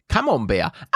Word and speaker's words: Camembert: Camembert.